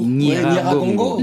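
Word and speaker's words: Nyiragongo: Pardonnez-moi si je le prononce mal. Niaragongo. – Niaragongo.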